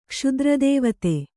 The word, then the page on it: ಕ್ಷುದ್ರದೇವತೆ: Kannada